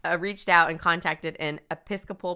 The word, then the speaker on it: Episcopal: Episcopal.